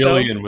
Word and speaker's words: Gillian: Gillian with a hard G.